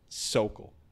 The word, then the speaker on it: Sokol: I'm here with Theo Sokol. Last name. How do you pronounce the last name? – Sokol. You got it Sokol.